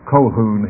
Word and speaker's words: Calhoun: John Caldwell Colquhoun, he would have pronounced it. He would pronounce it as if it was an O, because he was seeking to preserve the Scottish pronunciation.